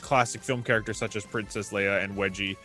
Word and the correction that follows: Wedge: Antilles, is am I saying that right? Because I don't watch Wedge. – Just Wedge.